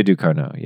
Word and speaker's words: Ducournau: How do you say her name? – Julia Ducournau, yeah.